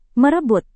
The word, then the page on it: merebut: Indonesian